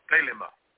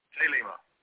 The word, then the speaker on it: Thelema: Thelema. Thelema.